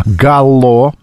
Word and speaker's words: Галло: Проект станет итогом работы современных художников в новой научной арт-резиденции «Галло». Ударение на «О». Вот такая вот выставка.